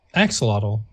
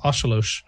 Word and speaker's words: axolotl: An anonymous Oshalosh, which is people think it's pronounced axolotl, but it's not because of like the native language that word comes from. It is pronounced Oshalosh.